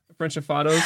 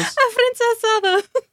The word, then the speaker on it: afrancesados: How do you say it? Frenchafados. – Afrancesados.